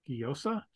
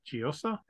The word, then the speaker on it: Giosa: Sue, I'm going to try to pronounce this, Sue Giosa, Giosa, it's a G-I-O-S-A. – Okay.